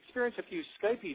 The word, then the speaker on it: Skype: I think we're expecting a few Skypey difficulties tonight. So Skypey. How do you say Skypey? – It's just Skype.